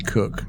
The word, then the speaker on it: Koch: is it Koch or Koch? – It's actually Cook. – Cook, okay, Christina Cook, 328 Days in Space, Kjell Lindgren, it's spelled K-J-E-L-L.